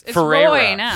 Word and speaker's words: Ferreira: How would you pronounce that? – Willa. She doesn't. – It's Ferreira. Ferreira.